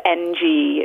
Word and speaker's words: Ng: It's N-G. It's Ng. I think that's how you pronounce it. – It is. You are so right. That is the way you pronounce it.